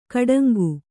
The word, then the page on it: ಕೊಡಂಗೆ: koḍaŋge